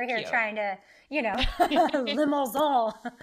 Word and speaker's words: Limousin: It's limousin. – Ooh. Ooh, what word is that? – Limousin. Think like limousine, but you say it like you're really French.